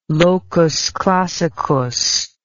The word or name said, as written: locus classicus